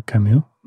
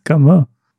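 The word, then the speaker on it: Comeau: Камо, да, мы его часто называем Камю, но к нам приходили, говорили, не-не-не-не, это французская фамилия, она читается Камо.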